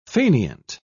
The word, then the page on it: faineant: British English